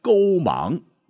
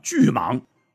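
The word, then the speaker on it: 句芒: 那其中一位唤作春神勾芒。哎，多说一嘴哈，这个勾芒，这个勾是句子的句，芒是光芒的芒，千万别念成什么巨芒啊。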